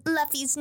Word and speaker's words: Luffy: I have seen so many TikToks where like, you're all saying Luffy's name wrong.